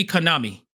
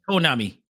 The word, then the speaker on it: Konami: The A is supposed to be pronounced as A. – Yeah. – So it should be Konami. – Yeah. – It should be Konami.